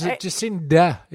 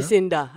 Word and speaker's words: Jacinda: No, let Jacinta be Jacinta. – Yeah. – You know, like I... – It's Jacinda, isn't it? – Jacinda, I think it is. Yeah, Jacinda. It's like Australian pronunciation.